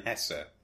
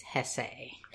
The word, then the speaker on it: Hesse: And for this episode, we read Beneath the Wheel by Hermann Hesse. Hesse? One of those two. It's two syllables. It's not Hess.